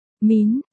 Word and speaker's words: Mân: La Thu Văn. Diệp Doanh Doanh nhìn bảng đọc từng chữ một, không đúng, La Thu Mân, Mân đọc là, Mín, Diệp Thiệu Bân sửa lại cho cô bé, Mân, chữ này là có ý nghĩa gì?